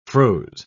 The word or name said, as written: froze